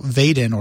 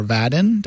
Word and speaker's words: Vaadin: to talk about Vadin or Vadin, depending on how you pronounce it, V-A-A-D-I-N, which is a Java framework for building modern web applications.